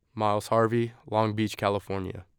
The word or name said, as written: Myles Harvey